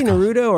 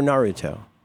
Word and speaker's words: Naruto: You say Naruto or Naruto? – I say Naruto. – That's what I have the emphasis on the Ru or Naruto.